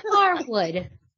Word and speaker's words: Harwood: It's Dax Harwood. There is no D in it. – Harwood. Huh. – Good to know. – I learned something new today. Harwood. Dax Harwood. – No D. No D.